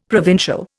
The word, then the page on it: provincial: Indian